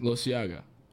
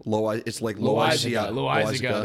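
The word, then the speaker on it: Loáisiga: Don't know how to say his last name. I'll never learn. – Loisiaga. – Loisiaga. – That's what it is. Yeah, no. – Loisiaga.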